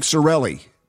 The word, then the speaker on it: Sarraille: Is it Mike Sarraille? – You actually got it. You nailed it.